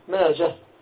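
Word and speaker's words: ماجه: وأما ماجة فهي على الراجح تكتب وتنطق هاء، ماجة تنطق هاء وتكتب هاء،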